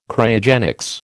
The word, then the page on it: Cryogenics: American English